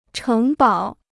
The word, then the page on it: 城堡: chéng bǎo